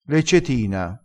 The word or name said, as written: leccetina